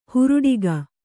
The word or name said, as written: ಹುರುಡಿಗ